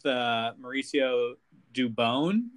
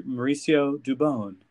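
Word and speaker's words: Mauricio Dubón: Breakout, if you don't count Webb, I'll go with Mauricio Dubon, which I guess is how he wants it to be pronounced now after listening to the radio, the sweet, silky voice of John Miller, Mauricio Dubon.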